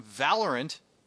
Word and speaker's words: Valorant: You can help me learn about Valorant. Did I pronounce that right? – You did, yes.